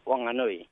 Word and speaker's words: Whanganui: We don't say Whanganui or Whanganui. It's Wanganui. So, it's some people call it a silent H.